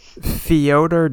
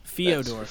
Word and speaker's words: Fyodor: And last but not least, Beirut's newest single, Fyodor Dormant. Call us up. Tell me how I pronounce that poorly, by all means. – Theodore? – Fedora. – F-Y-O-D-O-R? – Oh, no. – Fyodor. – Fyodor? – Fyodor.